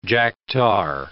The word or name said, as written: jack-tar